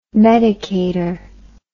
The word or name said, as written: medicator